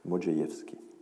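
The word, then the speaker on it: Modrzejewski: Euh, Joseph Mélez euh, a souvent raconté cette histoire, et donc le RZ se prononce je, Modziejewski.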